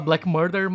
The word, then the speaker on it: Black Merda: Só que ele não se escreve murder como em assassinato, ele se escreve Black Merda. Merda mesmo. É, mas, gente. Sim, se, é, se, fala Black Murder, mas se escreve Black Merda.